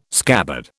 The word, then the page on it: scabbard: British English